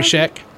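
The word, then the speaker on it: Scheck: Barry Schneck? How do you say that? – Barry Sheck. – Sheck? – Yeah, that's right. Schneck.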